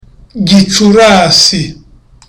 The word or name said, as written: gicurasi